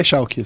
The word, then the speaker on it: Schalke: Eu até tava, tava falando, é, então, a Fnatic ganhou do, do Schalke, eu falo Schalke. – Hum, é Schalke, você tá é tá falando certo.